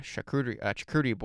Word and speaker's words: charcuterie: We had a charcuterie board. How do you say that word? Charcuterie.